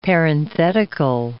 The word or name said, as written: parenthetical